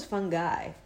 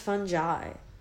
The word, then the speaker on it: fungi: I thought it was Fun Guy. It's pronounced fungi, okay? First of all, learn that.